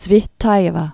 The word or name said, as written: Tsvetaeva, Marina Ivanovna